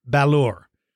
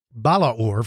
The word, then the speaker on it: Balaur: When I was doing research on how to pronounce its name, it's spelled B as in boy, B-A-L-A-U-R, Balur. That's how I would pronounce it. But a friend of mine who's from Romania, I called him and said, how would you pronounce this? He said, I would pronounce it Balaur, like three syllables, Balaur, with the second A being emphasized, Balaur.